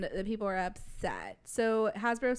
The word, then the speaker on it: Hasbro: So, Hasbro's came out this week, and is that how you say it? Hasbro's? – Mm-hmm. Yeah. Hasbro. Right. I thought it was Hashbro.